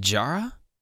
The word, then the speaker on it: Diarra: Is it Jara? – Yeah, it's like Jara. Giada. Yeah, it's like oh, really? Giada. I've been saying it wrong for years.